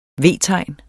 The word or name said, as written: V-tegn